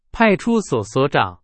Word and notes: 所长: Chinese

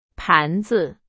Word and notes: 盘子: Chinese

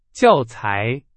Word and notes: 教材: Chinese

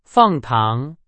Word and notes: 糖: Chinese

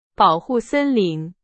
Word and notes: 森林: Chinese

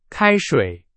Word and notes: 开水: Chinese

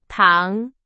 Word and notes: 糖: Chinese

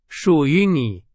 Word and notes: 属于: Chinese

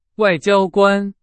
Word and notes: 外交官: Chinese

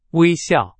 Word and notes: 微笑: Chinese

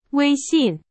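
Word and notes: 微信: Chinese

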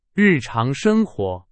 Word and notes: 日常: Chinese

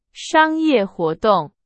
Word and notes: 商业: Chinese

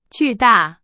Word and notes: 巨大: Chinese

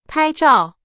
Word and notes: 拍照: Chinese

